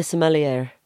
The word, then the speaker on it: sommelier: I want to be a sommelier.